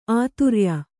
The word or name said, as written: ಆದೊರೆಯ